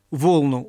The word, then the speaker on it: волну: как волну убелю». Волну — ударение именно на первом слоге.